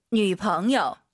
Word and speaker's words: 女朋友: 女朋友. – 니 朋友, 남자친구와 발음이 달라요. 성조가 남朋友, 니 朋友 아닙니다.